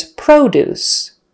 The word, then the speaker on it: produce: Spelled exactly the same way as wound, W-O-U-N-D. Now we have produce versus produce, produce. Spelled exactly the same way, P-R-O-D-U-C-E. So the noun is produce, produce. Syllable stress on the first syllable, produce with a long O, O-O, produce. The verb is produce. Actually, we kind of use that schwa sound in the first syllable, produce, produce. They produce cars at the factory.